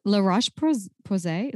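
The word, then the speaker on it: La Roche-Posay: It's La Roche Posay.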